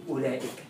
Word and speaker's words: أولئك: طيب تعالوا شوف كلمة زي أولئك هذه الواو لا تنطق واللام بعدها ألف تنطق ولا تكتب.